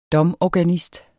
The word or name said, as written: DOMORGANISTER